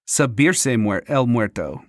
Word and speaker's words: subirse el muerto: Oh, I think I pronounced the Spanish word wrong. It's subirse el muerto.